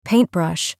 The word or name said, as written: paintbrush